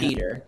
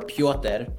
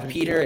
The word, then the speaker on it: Petr: um, it's it's spelled P E T R. You say it yeah. Peter. – Technically, yeah. you could say it. well. Technically, it's said it's Peter or Piotr, which is how you would say it. – Piotr. Uh, Piotr. Yeah. But I, I go by Peter, and it's spelled P E T R.